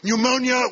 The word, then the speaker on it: pneumonia: Pneumonia, we know the P is silent, as indeed it is in swimming pool.